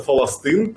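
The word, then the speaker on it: фаластын (فلسطين): Слово «Палестина» у них тоже нет, у них это называется «фаластын», поэтому я их называю «фалестинцами».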